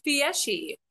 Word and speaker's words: Fieschi: How do you sp pronounce the last name? – It's Fieschi. – That's what I thought.